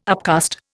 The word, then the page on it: upcast: Indian